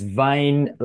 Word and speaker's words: Wyn: And right on the entry, you don't pronounce it Wynyard or Wayne. It's Wyn, like vein in your body.